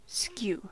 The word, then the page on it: skew: American English